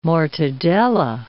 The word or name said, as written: mortadella